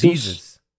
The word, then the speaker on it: Jesus: I just want to pronounce it right. Jesus. – It's Jesus. – Jesus, like Jesus.